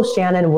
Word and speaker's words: Shannan: So S-H-A-N-N-A-N. If you Google Shannon Wilson, my stuff pops up.